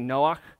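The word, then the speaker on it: Noach (נֹחַ): Noah is pronounced in Hebrew would be Noach.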